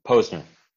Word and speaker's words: Posner: Did I say that right? Posner or Posner? – Posner.